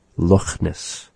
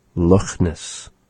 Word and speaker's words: Loch: So he pronounces that CH in the word just like how we say the Ch, Loch Ness. Loch Ness. It is sort of a raspy sound resembling that of the H sound.